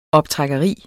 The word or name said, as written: optrækkeri